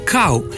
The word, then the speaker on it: kal: Odgovor na vprašanje deloma ponujajo priročniki, in sicer slovenski pravopis, ki pravi: kav v pomenu mlaka bi dejansko brali kav, medtem ko sta v pomenu zelena kal, kal krompirja, klica in podobno možni obe obliki izgovorjave, kal in kav.